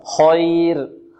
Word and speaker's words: خير: خير لك، خير الراء ماذا؟ الراء مفخمة، خير.